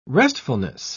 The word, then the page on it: restfulness: British English